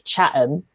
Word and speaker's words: Chatham: I grew up in a town called Chatham, except no one pronounces the T, so you call it Chatham.